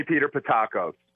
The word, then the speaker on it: Pattakos: You got to call me Peter Pattakos.